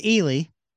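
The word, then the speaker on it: Ehle: Samantha Morton and Jennifer Ailey. I'm not sure how to pronounce her last name. A-H-L-E. They both play past Weinstein employees in the UK. And this is about... – It's Ely.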